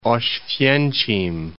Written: Oswiecim

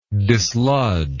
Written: dislodgment

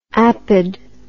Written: apod

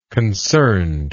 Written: concernedness

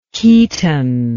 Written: keaton

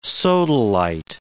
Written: sodalite

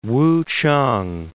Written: Wuchang

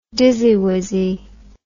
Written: dizzywizzy

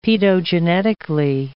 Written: paedogenetically

